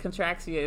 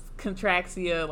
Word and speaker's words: Contraxia: Yes, and then after that scene, we went to Contraxia. Mm-hmm. Yes, that is how you say that weird-ass name. – So, they, like, that was a little bit on the nose for me, because we end up seeing, like, Contraxia is like, this freaky-ass planet with all, like, these sex workers. – Those, like, sex robots. – Contraxia, like, contract.